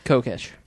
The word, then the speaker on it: Kokesh: And obviously, someone within government ranks is terrified of this happening, or we wouldn't have people like Adam Kokesh. Is that how his name's pronounced? – Mm-hmm. Kokesh.